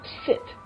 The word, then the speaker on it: sit: Sit 是一个动词，坐下来，S I T sit。那我们常常这个发音如果不够准确的话，Sit down please，哎，这也是不好的发音哈。 Sit down please，sit，它是一个短母音的。